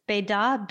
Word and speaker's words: Bedat: It's Bédart, B-E-D-A-T.